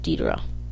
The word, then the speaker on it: Deidara: There are a lot of pronunciations. I end up pronouncing it Deidara, because that's how Sasori pronounces it.